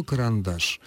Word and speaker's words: карандаш: Слово «карандаш». В нем три слога. Ударение падает на третий слог.